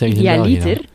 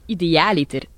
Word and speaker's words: idealiter: Dit woord spreek jij waarschijnlijk verkeerd uit. Het is niet idealiter, maar wel idealiter. De klemtoon op de A.